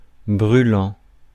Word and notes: brûlant: France; [bʁy.lɑ̃]

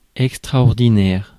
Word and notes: extraordinaire: [ɛk.stʁa.ɔʁ.di.nɛʁ]; /ɛk.stʁɔʁ.di.nɛʁ/

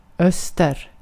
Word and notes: öster: /œster/

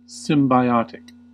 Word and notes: symbiotic: American English

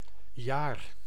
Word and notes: jaar: [jaːɾ]

